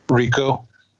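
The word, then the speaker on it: Ricou: And I'll give it to Rico, or how do you say his name? – Rico. – Rico, he did a lot of swimming in that.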